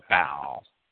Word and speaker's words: Baal: Some people don't like my pronunciation of Baal. If you look at Strong's dictionary, Strong seeks to make the word into two distinct syllables. And Strong's writes it as B-A-H-A-L, Ba-Al. Ba-Al is easily elided into Baal.